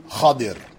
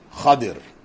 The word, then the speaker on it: Khidr: So beginning with the story of Musa and Khidr, and the proper pronunciation of Khidr's name, though we all know him as Khidr, and in some countries it's mispronounced as Khizr, and a variety of other things, it is actually Khadir. Khadir. Not Khadir, but Khadir. That is the correct pronunciation of the name of the teacher of Prophet Musa.